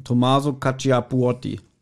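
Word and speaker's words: Tommaso Cacciapuoti: Wie spricht man den Namen aus? – Thomas Cappuccino. – Tommaso Cacciapuotti. Ja.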